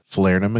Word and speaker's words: Falernum: Um, you've had the Falernum again. Is that how you say it? Um, yeah, right.